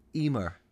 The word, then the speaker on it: Emer: Emer. Emer. – Oh. – Not written anything the way not how it's spelt. But Emer.